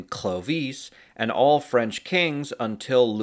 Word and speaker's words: Clovis: Well, first, the English way of pronouncing Clovis is probably not the original pronunciation, which was probably pronounced more like Clovis, with the cl coming out as one sound, while the s at the end of the name was very soft.